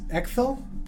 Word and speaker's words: Ecthel: My name is Ekthil. – Ekthil? – Yes. – It's spelled E-C-T-H-E-L.